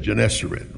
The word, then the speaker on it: Gennesaret: And he said, Gennesaret.